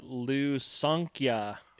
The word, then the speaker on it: Lusankya: The Lusankya.